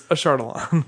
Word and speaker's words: Ashardalon: Yeah, but there's a myriad of pronunciations all around podcasting as to how that's pronounced. It's Ashardalon.